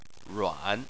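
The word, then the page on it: ruan: tone 3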